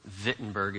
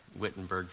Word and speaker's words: Wittenberg: October is the 500th anniversary of the nailing of the 95 Theses on the door of Wittenberg, if you're going to say it right. Wittenberg for the rest of us.